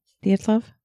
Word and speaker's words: Dyatlov: Dyatlov? – Yeah. Dyatlov.